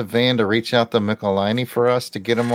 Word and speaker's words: Michelinie: And they wrote back, it rhymes with pickle miney. – So it's Michelinie. – Michelinie.